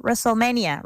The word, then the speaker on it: WrestleMania: Restless Mania o Restless Mania, Russell, Russell, WrestleMania, WrestleMania.